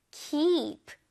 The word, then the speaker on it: keep: 이거 발음할 때 kept가 아니에요. 이가 두 개이니까 길게 발음해줘볼게요. 준비됐어요? keep, keep.